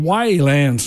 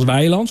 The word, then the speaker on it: Weiland: En dan heb ik er nu nog eentje van Scott Weilands. Je schrijft het als Weiland, zoals wij het hier zeggen. Maar het schijnt, je moet het uitspreken als Weiland.